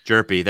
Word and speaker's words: Jerpy: Cooper, I always butcher this name. Chris Hirpy, Jerpy, there you go. Jerpy's always no age. – Yeah, no age. Just drop the age, Jerpy. Like, HJ should never be put back-to-back. That's just a weird combination of letters.